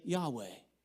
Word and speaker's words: Yahweh: Jehovah. We don't really know if that's the way it should be pronounced. Some say Yahweh.